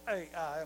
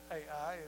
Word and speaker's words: Ai: Now, it's spelled Ai, and a lot of people call it Ai. It's Ai.